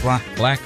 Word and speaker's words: 6LACK: Spelled the number 6, L-A-C-K. In case you ever try to look for him and you're wondering, like, why can't I find him? Yep, it's pronounced black, but it's spelled 6 black.